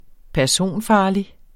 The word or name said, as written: personfarlig